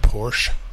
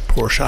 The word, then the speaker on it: Porsche: A chap called Ferdinand Porsche. Mm. Or some people pronounce it Porsche. – Porsche. Mm-hmm.